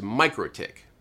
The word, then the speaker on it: MikroTik: the most common and generally accepted pronunciation is the English pronunciation, which is Mikrotik.